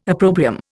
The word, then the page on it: opprobrium: Indian